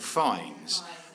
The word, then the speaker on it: Fiennes: Um, his name is Ranulph Fiennes, or Fines. And, um, uh, and a lot of you, uh, you, are correcting my pronunciation.